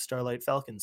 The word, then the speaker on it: Starlight Falcon: My name's Starlight Falcon. Spell it like it sounds.